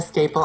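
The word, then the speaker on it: Stapel: On Facebook at Nina Staple.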